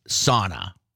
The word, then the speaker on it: sauna: Pronounce this word. S-A-U-N-A. – You can't pronounce sauna. No, you say suna. You're wrong. I'm right. – The correct pronunciation is sauna.